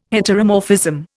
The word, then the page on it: heteromorphism: Indian